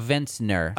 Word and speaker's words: vintner: A vinter? Okay. So in this game, you play a vintor. A vintner. – I don't know how I feel about vintner. – It's got two syllables.